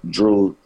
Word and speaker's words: Dreux: So I started, you know, my name is spelled French. It's Drew, D-R-E-U-X, because I was born in New Orleans.